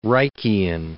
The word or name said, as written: Reichian